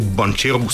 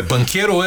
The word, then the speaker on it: Банкеро: Между другото, аз наистина на научих правилното произношение на Пао Банчеро, си го наричах да. до сега, пък се оказа, че Банкеро е... – Банкеро е, това си ейч на италиански винаги е Банкеро, но както и да е.